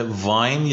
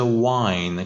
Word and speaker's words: wine: میشه وین یا میشه واین؟ – ببخشید، واین.